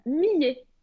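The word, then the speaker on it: Miye: Et comment c'est quoi la marque Tu peux nous dire C'est Millet.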